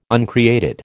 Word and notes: uncreated: American English

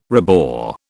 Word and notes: rebore: British English

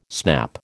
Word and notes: snap: American English

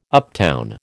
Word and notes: uptown: American English

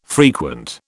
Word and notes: frequent: British English

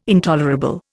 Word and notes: intolerable: Indian

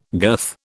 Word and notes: girth: British English

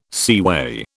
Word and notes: seaway: British English

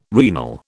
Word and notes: renal: British English

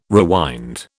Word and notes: rewind: British English

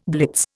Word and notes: blitz: Indian

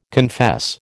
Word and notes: confess: American English

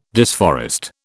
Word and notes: disforest: British English

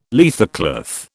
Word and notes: leathercloth: British English

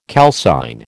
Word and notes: calcine: American English

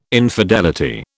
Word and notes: infidelity: British English

